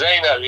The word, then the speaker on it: Zeynel: Yes, Zeynel, yeah.